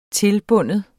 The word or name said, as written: tilbinde